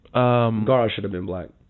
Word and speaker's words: Gaara: I only say that because Gaara from, I'm said that wrong. It's like Gaara, something like that. From Naruto. – Gaara.